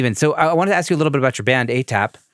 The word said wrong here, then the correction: APAT: Oh, I pronounced it wrong. It's APAT.